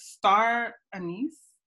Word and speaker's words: star anise: And I have, how do I pronounce this? Star anise? – Yes.